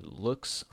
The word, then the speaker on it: Loox: A good one that I like to use is called Looks Photo Reviews. So that's spelled LOOX Photo Reviews, right?